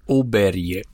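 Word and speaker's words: Åberg: Hoe spreek je Ludwig Aberg uit? – Oberje.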